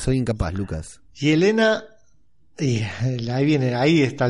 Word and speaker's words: Yelena: Y Elena Velova. – I know Velova, pero, es con velario... se pronuncia. Es medio raro el Velova. – Bien. – Pero es Yelena.